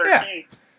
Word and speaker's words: Urquidy: I think that's Urquidy. Who it is. – Urquidy. I forgot about Urquidy.